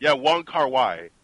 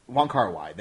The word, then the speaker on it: Wong Kar-wai: How do you say his name again, the director? – Oh, fuck, I know what you're talking about. Wong Kar-wai. – Wong Kar-wai, there we go.